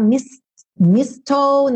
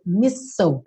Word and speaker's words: mistletoe: Que você não vai falar mistletoe, nem mistletoe, é mistle.